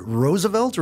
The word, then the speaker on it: Roosevelt: After his inauguration, Roosevelt calls... – Hang on. Are you saying it right, or am I? Is it Roosevelt, or is it Roosevelt? – I think they're both actually correct.